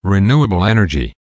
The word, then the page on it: renewable energy: American English